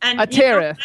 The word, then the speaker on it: Atereth: Atereth. – You know, I had a fabulous DJ tell me how everybody can remember it. Ready? – Uh-huh. – Atereth, like tearing up the charts.